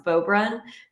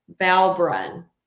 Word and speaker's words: Beaubrun: The two announcers they had because they clearly had no experience with any sort of Cajun name because they could not pronounce Beaubrun. They kept saying Balbrun, Balbrun, multiple times. And I'm like, have you never seen the Cajun EAU? It's I, I oh, guess not.